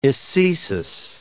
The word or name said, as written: ecesis